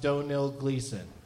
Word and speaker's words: Domhnall Gleeson: and I've been told Domhnall Gleeson is pronounced Domhnall Gleeson. I said it right both times this time.